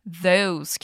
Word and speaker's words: those: A gente viu também a pronúncia em especial do those, que é um som de Z com a língua entre os dentes.